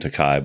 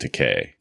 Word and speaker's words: Takei: People call him Takai, but he pronounces it Takei.